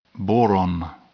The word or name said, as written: boron